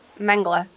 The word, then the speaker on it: Mengele: Because you hear, is it Mengele? – Dr. Mengele. – How do you pronounce it? – I pronounce it Mengele. – Mengele, Dr. Gotcha. Mengele, yeah. I have no idea.